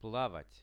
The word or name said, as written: plavat